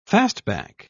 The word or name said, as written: fastback